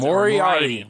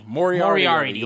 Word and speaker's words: Moriarty: Bryan Danielson versus Minoru Suzuki and Bobby Fish versus Lee Moriarty, I think is how you pronounce Moriarty. Moriarty. You like the Sherlock Holmes villain.